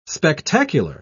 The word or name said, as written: spectacular